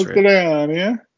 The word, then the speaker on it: Mastroianni: I don't know how to say his name. Mastroianni. I feel like that's Mastroianni. – Right.